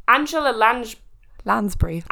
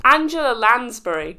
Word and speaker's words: Angela Lansbury: Angela Lange. The teapot in Beauty and the Beast. – Taylor's oldest. Angela Lansbury. – Angela Lansbury.